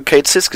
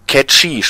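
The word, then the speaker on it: Cait Sìth: Und man hat ja auch schon tatsächlich das erste Mal Kate Sis gesehen. – Ja, stimmt. Ja. – Und, oder wie ich erfahren habe, der heißt ja nicht Kate Sis, der heißt ja Catchy, spricht man das ja eigentlich aus, weil es auf einer.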